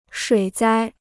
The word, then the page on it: 水灾: shuǐ zāi